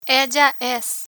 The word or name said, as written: ella es